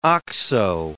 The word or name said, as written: oxo